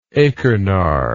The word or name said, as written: achernar